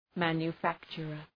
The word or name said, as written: manufacturer